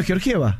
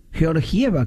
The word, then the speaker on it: Georgieva: Bueno, acá hay un oyente que nos dice en el YouTube, en el chat de YouTube, señores comunicadores, como el nombre es de origen eslavo, se pronuncia Georgiev. ¿Cómo lo pronunciamos nosotros, Cristalina? – Sí, está como ella lo escribió. – Cristalina Georgieva. – Claro. Sí. Georgieva, claro.